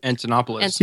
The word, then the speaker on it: Antonopoulos: Antonopoulos.